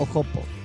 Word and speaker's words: Hoppo: O sea, se escribe H-O-P-P-O. Hoppo o Hoppo. No sé, como quieran decir. – Hippie. – Yo le digo Hoppo.